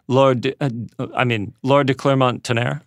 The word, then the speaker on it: Laura de Clermont-Tonnerre: I wanted to ask you about the on-set stuff, because you were walking, and forgive me if I mispronounce the name, but Laura de Clermont-Tonnerre? – Laura de Clermont-Tonnerre.